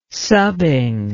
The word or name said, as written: subbing